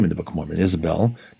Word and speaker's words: Isabel: Now, remember that Jezebel, or Isabel, as it would have been said, well, that's the way it is in the Book of Mormon, and that's how it would have been pronounced, right? It didn't have a J sound like that.